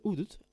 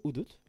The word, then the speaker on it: Hudut: Le Udut, je vous rappelle que ça s'écrit H-U-D-U-T, est né dans les villages côtiers Garifunas du Belize, principalement d'Andriga, Hopkins, Punta Gorda.